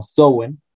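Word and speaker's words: Samhain: Eh, pero realmente la más importante es la de que se conoce como Samano Samaín, eh, que pues en su dialecto se pronunciaba como Sowen.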